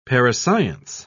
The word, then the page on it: parascience: British English